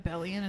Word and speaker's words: abelian: Is it abelian? Is that how you say it? – Yeah.